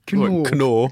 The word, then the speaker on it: Knorr: Well, maybe I'm saying it wrong. – Yeah, no. Knorr. – Knorr.